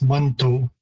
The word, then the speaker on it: Manitou: The word is typically pronounced Manto. So there's a number of different shifts again in terms of pronunciation from the spelling.